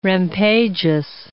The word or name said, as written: rampageous